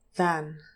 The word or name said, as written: than